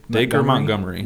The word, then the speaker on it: Dacre Montgomery: Dacre Montgomery.